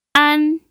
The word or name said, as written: an1